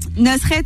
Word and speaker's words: Nusret: Nusret, c'est comme ça qu'on prononce. J'ai un peu du mal.